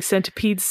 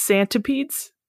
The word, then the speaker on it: centipedes: And he keeps calling centipedes santipedes. S-A-N-T-I.